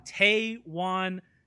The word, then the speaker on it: Taywan: Taywan Taylor.